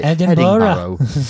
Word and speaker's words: Edinburgh: Edinburgh.